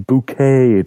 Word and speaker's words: Bucket: Well, there's a sitcom in Britain where there's a lady called Harriet Bucket, but she pronounces her surname Bouquet, darling, to get around that.